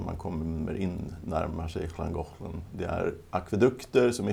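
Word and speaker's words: Llangollen: Uttalas, ja, Schlangochlen men stavas Langollen. Eh, den går in i Wales.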